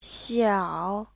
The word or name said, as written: xiao3